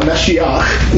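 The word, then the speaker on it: Mashiach (משיח): Well, Messiah, Mashiach, is the Hebrew pronunciation of it.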